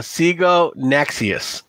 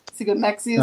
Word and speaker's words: Siganaxius: That's Siganactus. – You're going to have to spell that one for me. – No. – Spelled like it sounds. Siganactus. – It's Siganaxius. – Siganaxius.